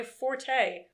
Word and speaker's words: forte: But see, I have a problem with this because I know I'm not, this is not my forte or fort, if you want to be classy. both pronunciations are correct, actually. – The more I did you not know that. Interesting. Yes. Hmm. – But nobody uses that because forte sounds way better.